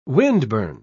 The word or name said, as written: windburn